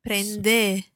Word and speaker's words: prende: Prende. – So just to be absolutely clear, that's a letter e, as in egg, but it's an e sound in Italian.